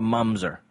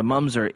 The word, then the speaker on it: mamzer: And there's a word in Hebrew called a mamzer, M-A-M-Z-E-R, mamzer, mamzer, a couple different ways to pronounce it. But this idea of the mamzer is, or the mumzer is, technically it's the bastard child.